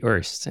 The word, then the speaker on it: Erst: It will be Erst. If you want to do an English podcast, you, you have to do the, the English thing.